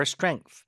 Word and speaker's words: strength: 그 다음에 We need time to grow back our strength. We need time 시간이 필요하다 to grow back our strength. To grow back our strength. Back에 힘 들어가죠. To grow back our strength. Strength TH 발음 마무리 잘해주시고 좋습니다.